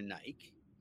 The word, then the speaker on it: Nike: A perfect example is Nike. It's spelled N-I-K-E. – Yeah. So, and it looks like Nikki. – it could either be pronounced normally as Nikki or Nike. – Yeah. – Based on English, Yeah. – So, they had to do a marketing job to sell it as Nike.